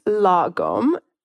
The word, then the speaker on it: lagom: Yeah, so it's pronounced lagom. You got it. You're pretty close.